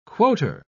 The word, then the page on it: quoter: British English